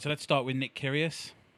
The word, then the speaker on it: Kyrgios: But I am a bit nervous about Nick Kyrgios.